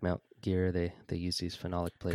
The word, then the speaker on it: phenolic: It's like a phenolic. – Yeah. – Nameplate. – It's pronounced phallic.